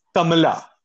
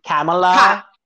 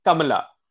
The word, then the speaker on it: Kamala: it's Kamala. It's not Kamala. – Or Kamala. – It's Kamala. I get that those syllables do not exist in the English language, so...